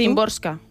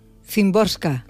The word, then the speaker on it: Szymborska: Szymborska. – Szymborska.